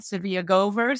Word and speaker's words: Tzivia Gover: And also my name, uh, Nobody else is named Tzivia Gover, so I'm easy to find if you can spell that T Z I V I A G O V E R.